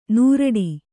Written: ನೂರಡಿ

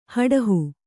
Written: ಹಡುಹು

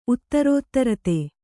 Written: ಉತ್ತರೋತ್ತರತೆ

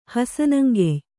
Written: ಹಸನಂಗೆಯ್

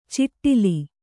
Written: ಚಿಟ್ಟಿಲಿ

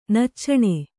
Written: ನಚ್ಚಣ

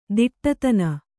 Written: ತುಡುತಿನ್

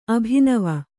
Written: ಅಭಿನವ

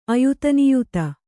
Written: ಅಯುತನಿಯುತ

